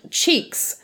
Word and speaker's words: Cheeques: And it is called Cheeks. And it is spelled... It is spelled C-H-E-E-Q-U-E-S.